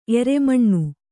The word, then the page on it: ಏರುಮಣ್ಣು: kannada